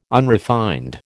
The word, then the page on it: unrefined: American English